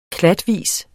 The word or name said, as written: klatvis